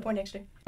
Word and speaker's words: Poindexter: Taylor Poindexter.